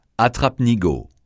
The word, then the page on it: attrape-nigaud: francais